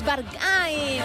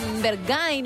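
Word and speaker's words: Berghain: Es Bargay, no Bergay, no como cada uno lo quiera pronunciar, pero efectivamente es esta canción súper conocida, lo decíamos, actual y que suena mucho.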